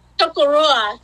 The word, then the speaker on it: Chocorua: But I was reading this morning about Chocorua. Did I say that right? – Chocorua, yes.